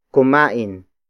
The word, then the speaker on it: kumain: Kumain ng ensalada, masustansya, yan. Kumain ng ensalada, masustansya, yan. The first word is kumain. Kumain. Kumain. Kumain is spelled as K-U-M-A-I-N.